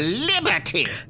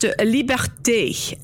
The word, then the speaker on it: Liberté: Mademoiselle Liberty! – It's pronounced Liberté, and it's Madame.